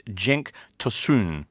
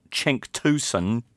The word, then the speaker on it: Cenk Tosun: And here is Turkish Football saying, listening to the podcast, this is how you pronounce his name. It is Cenk Tosun, not Cenk Tosun, as I'm sure he will be called.